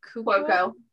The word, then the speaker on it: Cuoco: Kaylee Cuoco.